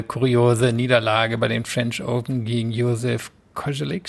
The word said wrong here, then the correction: Kovalik: spricht man den so aus? – Kovalik.